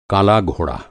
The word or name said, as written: घोड़ा